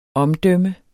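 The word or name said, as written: OMDØMMERNES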